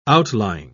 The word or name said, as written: outlying